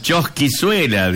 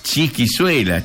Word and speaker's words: chisquizuela: Yo le decía chosquizuela, dice, y no era así, es chisquizuela. Acá se dice así, ahora me voy informando, dice, mejor gracias.